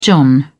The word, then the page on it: john: British English